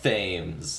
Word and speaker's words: Thames: I believe it's pronounced Thames.